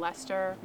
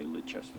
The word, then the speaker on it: Leicester: We are in Spidergate Cemetery in Leicester, Massachusetts. – Or as I like to say, Leicester. – That's not how you say it.